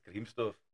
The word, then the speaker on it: Gremsdorf: Wie man einen Dorfnamen so unterschiedlich aussprechen kann. Ne? Aber ich bleibe dabei, bei uns in der Region, und das ist ja so, heißt es Grimsdorf.